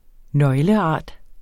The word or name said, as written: nøgleart